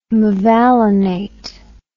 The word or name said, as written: mevalonate